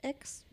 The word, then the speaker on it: Axe: Benutzen eigentlich erwachsene Männer auch Axe? Ex? Wie sagt man denn dazu? Ich würde es Ax aussprechen. Das ist so ein jugendlichen Ding, oder?